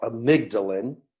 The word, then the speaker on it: amygdalin: So that specific substance is called amygdalin.